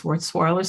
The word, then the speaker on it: Wurzweiler: Well, with that in mind, I know that I'll share my screen here, and I believe you attended, I'm probably going to butcher this name, but Wurzweiler School of Social Work. Is that correct? – Yes, this is correct. It's Wurzweiler School of Social Work.